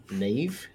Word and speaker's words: Neve: I just saw the fucking posters and yeah, Neve, Nev, Neve.